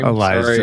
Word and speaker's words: Eliza: Jamie Lee Curtis, Tom Arnold, Bill Paxton, Tia Carrera, Elijah Dushku, or however you pronounce her name. – Eliza, sorry.